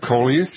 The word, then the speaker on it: coleus: One has to do with the mandevilla, and the other is with the, I don't know if I'm pronouncing it correctly, coleus, C-O-L-E-A-S, or E-U-S? – Yeah, coleus, yes.